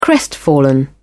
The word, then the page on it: crestfallen: British English